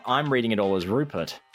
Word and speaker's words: Ruphart: Yeah, I pronounce it like Rufart because that's kind of how Penn says it, like as in roof on a house and heart as in the organ. – Yeah, so you couldn't see that in the book, the name is spelled R-U-P-H-A-R-T, Rupart.